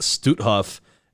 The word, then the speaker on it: Stutthof: and it's pronounced Stutthof, and the mass murder that took place inside was only able to take place with your help.